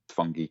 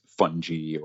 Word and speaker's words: fungi: And I pronounced fungi kind of like the Italian pronunciation as kind of a nod to my working with guys from Italy. A lot of people in the mushroom community would say it's fungi or something similar.